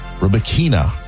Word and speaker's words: Rybakina: Coco Gauff onto the semifinals where she will face second seed Elena Rybakina.